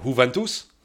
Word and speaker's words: Juventus: For Juventus? – Mm-hmm. – Nice. – Yeah, it doesn't. You don't pronounce the J.